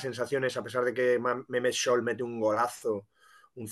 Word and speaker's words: Mehmet: que nos han recriminado en los comentarios que se pronuncia así y además nos dijeron que no solo nosotros, sino en general los periodistas, esto te incluye a ti, nos preocupamos muy poco de cómo se pronuncian los nombres y que se dice Mehmet.